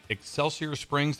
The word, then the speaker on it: Excelsior Springs: So, Chris, this last weekend was in Excelsior Springs. Did I say that right? – Yes. – That sounds right.